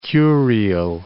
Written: Kurile Islands

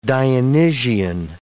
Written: Dionysian

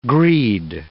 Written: greed

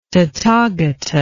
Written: tathagata

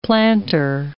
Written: planter

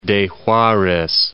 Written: Acapulco de Juarez

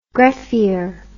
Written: greffier

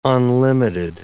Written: unlimited

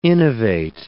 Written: innovate

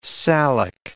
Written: salic

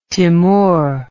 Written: timour

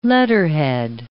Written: letterhead

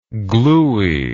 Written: glueyness

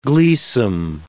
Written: gleesome

